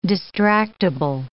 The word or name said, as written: distractable